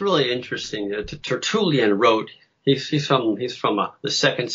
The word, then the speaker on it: Tertullian: Tertullian? – Tertullian, yeah.